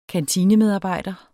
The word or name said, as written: kantinemedarbejder